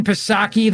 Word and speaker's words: Psaki: As he was analyzing it last night, along with the band of merry Trump haters, Rachel Maddow, Joy Reid, Jen Psaki, the P is silent for panic, and Chris Hayes.